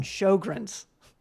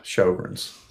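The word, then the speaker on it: Sjögren: Sjogren's. – Yeah, Sjogren's. It's got a silent J in there.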